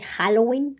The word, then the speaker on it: Halloween: el Halloween con J, obviamente, porque pues aquí es este, así se pronuncia aquí en México, con J. Si tú lo pronunciabas de otra forma, te voy a decir que estás mal, porque estás en México y tienes que pronunciarlo con J como si fuera ja, así como cuando te ríes, pero más o menos así como de Halloween con un acentito, pero tú le vas ahí variando, ¿no?